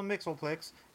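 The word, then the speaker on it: Mxyzptlk: just like Mr. Mixelplix. That's the pronunciation that I'll be going with for today for the win.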